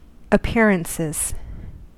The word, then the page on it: appearances: American English